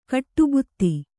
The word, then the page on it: ಗಡಿಭತ್ತ: Kannada